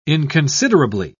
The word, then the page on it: inconsiderably: British English